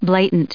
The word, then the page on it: blatant: bla*tant; American English